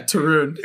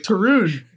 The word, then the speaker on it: Tarun: I was like, Tarun, it's like maroon with a T.